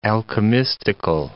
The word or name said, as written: alchemistical